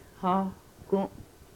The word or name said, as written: hohkum